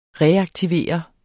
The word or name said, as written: reaktivere